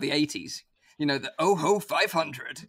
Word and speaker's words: Ojo: It's actually pronounced, if you watch the trailer and listen to the guy, he pronounces it the OHO.